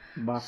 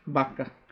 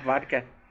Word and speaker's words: vaca: My previous neighbor, I don't pronounce V, bro. Oh. Like, I'm like, hey, Victor. – But you just... – Victor, ven para acá. Because it's Victor, vente, Bueno, everything's bah, bah. For me, because I was raised in Chihuahua. – Vaca. – So. – Vaca. – Vaca, yeah. – Not vodka.